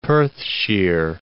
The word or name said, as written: Perthshire